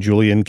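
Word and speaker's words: Julián: Listen to Representative Julian, or I guess he pronounces his name, Julian Castro.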